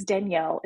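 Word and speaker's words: Danyell: My name is Danielle.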